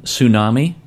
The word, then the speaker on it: tsunami: Tsunami, the T is silent.